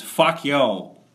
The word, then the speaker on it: facio: Is F-A-C-I-O. And the way it's pronounced is fuck yo.